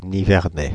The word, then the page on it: Nivernais: Paris; IPA: [ni.vɛʁ.nɛ]; French